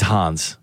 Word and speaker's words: Hans: Mandy, are you a Hans fan? – Yes, I am. – I think he's Hans. He's German. – I was trying to rhyme with fan.